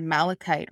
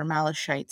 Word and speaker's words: malachite: So wealth, luggage when you're traveling, you can add malachite or malachite. Some people pronounce it like that.